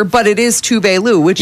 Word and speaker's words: Tuvalu: The big one, Tovlo. Is Tuvalu.